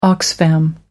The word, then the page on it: oxfam: American English